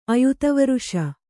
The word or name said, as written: ಅಯುತವರುಷ